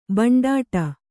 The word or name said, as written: ಭಂಡಾಟ